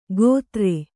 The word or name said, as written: ಗೋತ್ರೆ